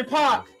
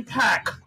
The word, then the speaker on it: Paak: That's Twin Flame by Anderson Paak, or how the white people say it, Anderson Pac. And Ka is actually pronounced K but he white, so I'm gonna misspell his name or mispronounce it.